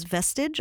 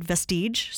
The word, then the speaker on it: Vestige: Interestingly, one of the big acquisitions I heard of was a virtual reality project called what I would pronounce as Vestige, although I met the project creators and they said Vestige, so it's V-E-S-T-I-G-E.